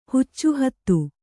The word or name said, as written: ಹುಚ್ಚುಹತ್ತಿ